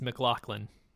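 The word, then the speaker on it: MacLachlan: I just watched a YouTube video on how to pronounce his name, and it's McLaughlin.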